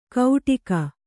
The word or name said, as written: ಕೌಟಿಕ